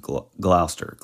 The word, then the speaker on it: Gloucester: I specifically looked up what looks like Gloucester, but it's actually just pronounced Gloucester, Gloucester.